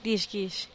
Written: dixgix

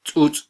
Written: tzutz

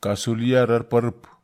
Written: kasulia rerparep

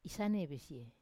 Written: isanevesie

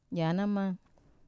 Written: yanama

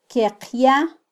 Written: kyeqya

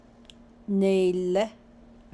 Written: neilé

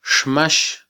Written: xmax

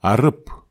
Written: arep

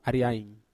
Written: ariain

